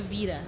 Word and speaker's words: Vita: She has some fun stuff, including something we've heard about, I think first heard about at E3, and I still don't know how to pronounce it, the Sony PlayStation... – Vita. – Vita? We're in agreement. It's the Vita? – It's the Vita.